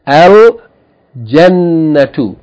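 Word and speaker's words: al jannatu (الجنة): If you add alif lam, the lam must be pronounced. Al babu. The third letter, jim. The example they gave, al jannatu.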